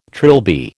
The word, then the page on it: trilby: American English